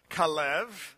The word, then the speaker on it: Caleb: In Hebrew, it's pronounced Kalev.